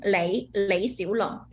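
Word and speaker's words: Lei Xiulong (李小龍): Lei Xiulong, yeah. So Lei is a family name, Xiu is like little, Long. Everyone like this name, is like dragon, Lei Xiulong.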